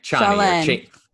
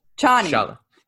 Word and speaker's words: Chani: How does you pronounce it? She's the girl that Paul saw in his dream. – No, yeah, Chani. – Chani.